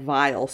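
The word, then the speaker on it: Viall: I grew up and I didn't pronounce it that way, but I just heard it said Vile so many times and I thought, oh, what a terrible name. – Vile. – You know, and it stuck with me because it's like Nick Vile. Like, when he was first on The Bachelorette, like, that's when I knew the pronunciation.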